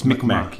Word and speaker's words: Mi'kmaq: Because when I look at it, I want to pronounce that Q on the end, but it's Mi'kmaq.